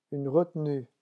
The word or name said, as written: une retenue